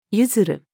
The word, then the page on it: 譲る: female